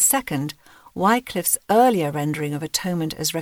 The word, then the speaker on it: atonement: We believe one final shift is called for in our thinking about atonement. Oh, that we still pronounced atonement as it would have been heard in William Tyndale's pronunciation, at-one-ment.